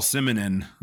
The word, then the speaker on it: Simonon: I never thought that Mick Jones or even Paul Simonon, hopefully that's how you pronounce his last name. I'm going to go with it. – That's how I do it.